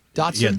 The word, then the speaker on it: dachshund: Anthony and Lori's dog, there was an iguana in the backyard, and it's like one of those little dachshund, is that how you say it? Dachshund? – Yeah, dachshund. – Dachshund? – Dachshund? – Yes. – Dachshund.